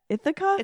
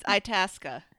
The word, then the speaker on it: Itasca: Ithaca? It's Itasca.